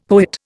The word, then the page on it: poet: Indian